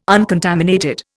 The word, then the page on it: uncontaminated: Indian